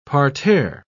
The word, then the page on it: parterre: British English